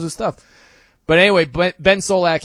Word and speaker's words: Solak: So the most entertaining watches, according to Ben Solak, how do you say his name? Solak.